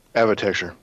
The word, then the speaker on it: Avatecture: Avatecture. – Thank you. I even wrote it phonetically. – Architecture, but with AV in the front.